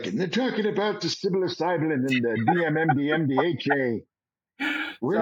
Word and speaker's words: psilocybin: That talks about psilocybin. That's the right way to pronounce it.